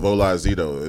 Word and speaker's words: Holaezito: But anyway, I go by the name of Olazito. It's spelled H-O-L-A-E-Z-I-T-O, just in case you want to follow me on IG, Twitter, Snapchat.